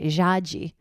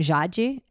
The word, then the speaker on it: Jade: So my name is Jade, hence the name of the podcast. But my given name is Jaji, which is also Jade. But in Portuguese, it's pronounced in a totally different way, Jaji.